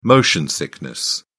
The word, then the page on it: motion sickness: British English